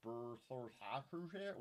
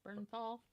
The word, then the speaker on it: Bernthal: Ben Affleck and John Berthelsacher will both return. – Okay. – How do you pronounce that? – Bernthal.